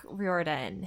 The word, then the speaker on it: Riordan: You both mentioned not having heard of the book, but you might have heard of Rick Riordan, who did the Percy Jackson series.